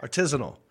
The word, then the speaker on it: artisanal: Say it like this and do what I – repeat after me. R. – R. – Tis. – Tis. – Anul. – Anul. Artisanal.